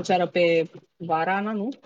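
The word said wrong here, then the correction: Varvara (Варвара): Varana ai zis. Eu nu pot să pronunț. – Varvara, Varvara. – Varvara, așa. Uh, să o ceară în căsătorie și ea acceptă până la urmă.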